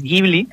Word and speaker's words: Ghibli: Pero él no sabía cómo se pronunciaba Ghibli. Entonces él lo tradujo como Ghibli, se equivocó. con lo cual la pronunciación buena es Ghibli,